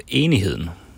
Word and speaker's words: Enigheden: Ja, det var ju ett danskt linjeskepp, så det heter faktiskt Enigheden.